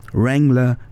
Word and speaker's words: Wrangler: Wrangler, Lee und Levi's. Die ersten beiden Markennamen wurden korrekt ausgesprochen, mal abgesehen von dem stimmlosen W in Wrangler.